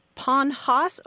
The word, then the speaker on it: Panhas: It's Pennsylvania Dutch, and, well, it's spelled a lot of different ways. P-A-N-H-A-S or... – Okay. – P-A-N-H-O-S-S. Some people call it Ponhos or pony horse.